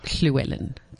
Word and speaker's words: Llewellyn: It's actually pronounced Llewellyn. It's Welsh.